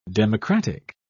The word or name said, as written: democratic